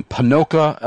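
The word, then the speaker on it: Ponoka: And before we go to our final break, which is a very brief break, we have a fellow Canadian writing in from Panoka, Alberta, Canada.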